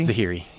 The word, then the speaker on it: Zahiri: Did I say it right? Zahiri? – Zahiri, yeah.